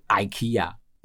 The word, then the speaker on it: IKEA: 瑞典音是 IKEA，美国腔是 IKEA。